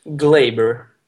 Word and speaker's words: glaber: Ja, det uttalas ju definitivt inte som jag sa det. Varför körde jag någon tysk accent på den? Det var jävligt onödigt. – Glaber. – Glaber.